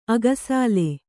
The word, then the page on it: ಅಗಸಾಲೆ: agasāle